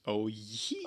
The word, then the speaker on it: Owyhee: Don't know how to pronounce that either. – Owyhee. – Owyhee. – Owyhee. – Mario, that's how potatoes sound in English.